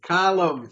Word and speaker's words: Callum: Callum. C-A-L-L-U-M. – We pronounce it Caleb in the States. – It's Caleb. It's Callum. C-A-L-L-U-M.